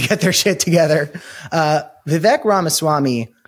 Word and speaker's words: Vivek: So it's pronounced Vivek.